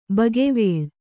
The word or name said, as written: ಬಗೆವೇಳ್